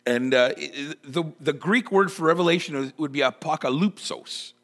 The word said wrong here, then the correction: apokalypsis: apokalypsis. I just totally butchered that. I was really hoping to really just totally impress all of you, and I just butchered it. Man, apokalypsis. There we go.